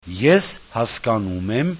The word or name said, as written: eshaskanumem